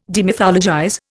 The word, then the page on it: demythologize: Indian